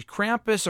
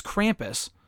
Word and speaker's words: Krampus: Krampus, or is it, how do you pronounce it? I thought it was Krampus. – Krampus.